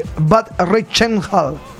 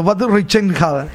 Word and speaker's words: Bad Reichenhall: Diana, la que nos escribe desde Bad Reichenhall. ¿Se pronuncia salsero en alemán? – Sí. – Estoy practicando. Bad Reichenhall, Alemania.